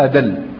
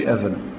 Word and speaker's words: أدل: معناها أدل، أدل بالدالة مش أذل، أدل أي أكثر دلالة، أنطق.